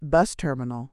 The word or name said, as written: bus terminal